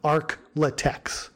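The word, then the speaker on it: ArkLaTex: Except in Louisiana, where Tillet and Madrill are referred to as the, and this is how it's spelled out in the paper, the Arc Latex champion. So it's shortened versions of Arkansas, Louisiana, and Texas. So ARK-LA-TEX, Arc Latex.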